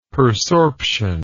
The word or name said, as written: persorption